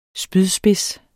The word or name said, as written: SPYDSPIDS